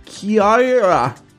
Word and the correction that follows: kia ora: But how do I say it? – Kia ora, I think.